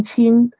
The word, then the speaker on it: Qin: The next one we see is the Qin dynasty, Q-I-N, Qin, and it lasts from 221 to 207 BCE. So pronounce this like C-H-E-E-N, Qin, but a hint to say this Chinese Q, say it while you're smiling with your teeth showing. That'll help you pronounce the Qin.